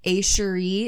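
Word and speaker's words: Echeri: A Cherie. It's like Sherry, like in the French, but it's E-C-H-E-R-I ceramics.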